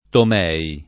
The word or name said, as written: Tomei